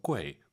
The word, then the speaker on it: 贵: 꾸이 아니고 UI 사이에 넣어서 꾀, 꾀 시 꾀 이렇게 발음하시고요.